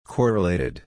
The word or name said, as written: correlated